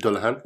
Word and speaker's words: Dullahan: Even, like, and I always remember the, where she's in the, I'm probably going to say it wrong, the Dullahan. – Ed Dullahan, yeah. – Yeah, Dullahan, okay.